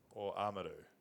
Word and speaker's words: Oamaru: Oamaru. – Oamaru. – It's a hard one because it's a big step from Oamaru. Oamaru, mate. – Having two vowels back to back and pronouncing them without blending them.